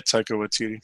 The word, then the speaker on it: Taika Waititi: Taika Waititi? – Yeah, Taika Waititi.